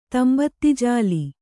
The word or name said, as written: ತಂಬತ್ತಿಜಾಲಿ